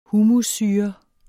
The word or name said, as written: humussyre